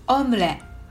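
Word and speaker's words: omelette: フランス語です発音はオムレと言います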